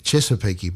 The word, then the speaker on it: Chesapeake: Chesapeake Bay.